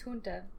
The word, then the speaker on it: junta: I think it's junta. – Junta. It's a junta. On May the 17th. – You can call it junta. Say it like a Bama boy.